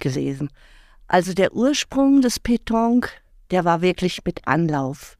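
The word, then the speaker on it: Pétanque: Sie haben es gerade sehr schön ausgesprochen. Ich habe es dann tatsächlich falsch gesagt. Ne? Mhm. Sagen Sie es nochmal, mhm. damit es die HörerInnen dann auch drin haben. Petanque. Okay, sehr schön.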